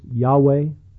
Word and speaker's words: Yahweh: Perhaps it would be pronounced Yahweh. Many call it Jehovah. That's the least biblical pronunciation of that name.